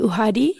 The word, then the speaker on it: uhadi: You play the uhadi and the umrube. – Yes. – Is that how you say it? – Umrube. Uhadi, umrube.